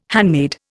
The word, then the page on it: handmaid: Indian